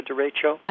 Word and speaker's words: derecho: I did not know what derecho. Is that how you say it? – Derecho. – Derecho. See, when you read, you don't know how it's said.